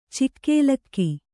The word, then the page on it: ಛಗಲಕ: Kannada